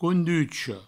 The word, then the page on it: conducha: counducho